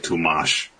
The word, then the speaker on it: Tolmache: I got the first part of Tolmash. – No, it's Leon Tolmache Tolmash. – Oh, Tolmache. Well, I pronounce the E extra hard because I know that's important to them. Even though he specified right there that it's not pronounced.